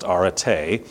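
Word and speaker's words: arete (ἀρετή): If you care, it's pronounced arete.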